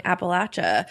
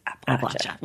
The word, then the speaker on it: Appalachia: Thank you, There's a lot of confusing and disagreeing interpretations of the pronunciation, but the one that I have heard and that people in West Virginia will like correct you is Appalachia.